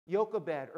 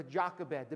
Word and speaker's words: Jochebed: We're going to take a look at a mom by the name of Jochebed or Jochebed, depending on how you want to pronounce it, Jochebed or Jochebed. Either one is correct, I'm guessing.